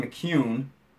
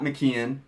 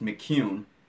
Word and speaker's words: McKeon: Sean McCune, it is not McKeon, and I have said that wrong more than a few times on this podcast, but Sean clarified for us the other night, it's pronounced McCune.